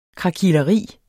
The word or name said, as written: krakileri